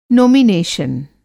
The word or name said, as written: नोमिनेशन